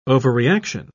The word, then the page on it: overreaction: British English